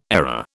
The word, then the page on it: ARA: British English